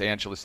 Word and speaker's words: Angelos: But, you know, I always say it's Angeles, like Los Angeles, because then it's easy for people to figure out.